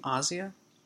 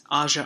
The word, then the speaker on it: Asia: Hashtag MeToo leaders are struggling to respond to the bombshell revelations that Asia Argento, is that how you pronounce her name? Asia? I've been saying Asia all week, and then I was corrected.